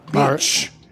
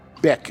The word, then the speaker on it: Bich: Marcel Bic. It's probably B I C H, mm-hmm. and it's pronounced Bic, maybe.